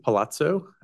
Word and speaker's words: Palazzo: My name is Dr. Ettore Palazzo, the CEO of Evergreen Health, and my last name is spelled P-A-L-A-Z-Z-O.